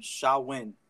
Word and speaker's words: Samhain: It was called Samhain, and it's spelled really ridiculous. S-A-M-H-A-I-N.